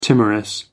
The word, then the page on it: timorous: American English